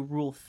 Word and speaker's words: ruleth: I have to say, did I pronounce that right? Mm. Rulth. – Rulth. – That's one of those words Rulth? that I've read. Ruleth. Ruleth. There we go.